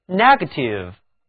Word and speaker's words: negative: 请注意在单词 negative 中，字母 NE 组合读作 ne ne negative，重音在开头，而到了 negator 这个词里面， NE 组合却读作 ni ni，重音也后移到了第二个音节上，就是 negator， negative and negator。